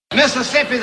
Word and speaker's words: Mississippi: Mississippi's I-double-P, I-double-S, I am. You pronounce it backward, ip, is, ism. Careful with that one.